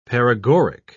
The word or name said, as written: paregoric